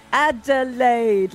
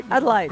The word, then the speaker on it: Adelaide: And you can always tell they don't know Adelaide when they pronounce it like Adelaide, like they've never seen the word before. – You've got to clap the syllables when you're trying to learn Adelaide. – Adelaide.